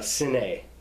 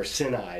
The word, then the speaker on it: Sinai: The I in Sinai is not a long I. It's Sine, or Sinai is how you'd say it.